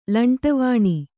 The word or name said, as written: ಲಂಟವಾಣಿ